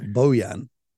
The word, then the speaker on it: Bojan: Or, Bojan, if you want to be correct, Bojan.